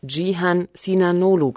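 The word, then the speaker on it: Cihan Sinanoğlu: Bei Jihan Sinanolu spricht man ihn Jihan Sinanolu quasi aus. Das C ohne Haken ist ein J, ein C mit Haken ist ein Tsch. Und das weiche G wie in meinem Namen wird quasi nicht gesprochen.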